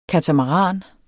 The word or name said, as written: katamaran